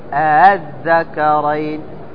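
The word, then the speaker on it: ءآلذكرين: وجه التسهيل أنك تنطق الهمزة الثانية بين يعني بين الهمزة وبين الألف، بين همزة وبين ألف، فتقول أذكرين.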